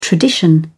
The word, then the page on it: tradition: British English